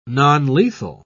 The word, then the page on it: nonlethal: British English